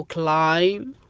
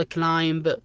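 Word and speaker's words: climb: كلايم. ما تنطق كلايمب ولا تنطق الكلمة الأولى اللي هي بامب ولا كامب، تنطق بحرف سايلنت البي.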